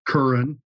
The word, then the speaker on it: Kerin: Sure, it's pronounced Curran, and it's spelled K-E-R-I-N.